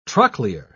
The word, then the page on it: trochlear: British English